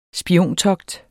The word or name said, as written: spiontogt